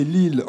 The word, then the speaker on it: Lisle: Mais là, c'est Lille en Dordogne. Ça s'écrit L-I-S-L-E.